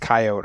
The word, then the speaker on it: coyote: It's pronounced coyote around here.